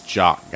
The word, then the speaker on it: Jock: I wonder about this Jock guy. It's spelled like jockstrap and not like French guy Jock.